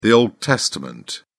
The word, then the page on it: old testament: British English